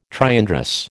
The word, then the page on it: triandrous: American English